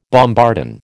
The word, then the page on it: bombardon: American English